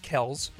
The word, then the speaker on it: Kelce: So we got into the Kelce brothers, but one of the stories was, that's wrong. It's pronounced Kels. – Nope. – Their dad got so tired of correcting people, he just let them call everybody Kelce, and so the boys grew up being called Kelce.